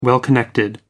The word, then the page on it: well-connected: American English